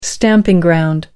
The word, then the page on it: stamping ground: American English